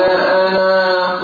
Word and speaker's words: أنا: قال أنا خير من، ما قلتش قال أنا خير من، ما قلتش كده، الألف واحدة فتحة نون وبس مش ألف عديمة. قال أنا خير من، طب لما أقف قال أنا لأن تثبت موجودة تنطق واضح.